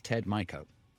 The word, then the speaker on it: Ted Mico: Yeah, my name is Ted Mico.